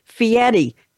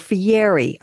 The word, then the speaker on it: Fieri: Have you ever heard of, if you've ever heard of Diners, Drive-Ins, and Dives, the cooking show, the restaurant show, it's Guy Fieri, F-I-E-R-I, or Fieri. I've heard it pronounced as though the R is a T.